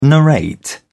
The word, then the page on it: narrate: British English